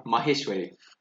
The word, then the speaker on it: Maheshwari: Maheshwari.